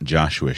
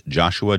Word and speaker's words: Joshua: Reach out to me on Twitter at Joshua Sheets. Remember, my name is spelled S-H-E-A-T-S. So my Twitter is username is at Joshua, J-O-S-H-U-A, Sheets, S-H-E-A-T-S.